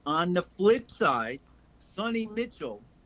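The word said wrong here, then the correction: Sony Michel: It's not Sonny Mitchell. – What the hell's his name? – It's Sony Michelle.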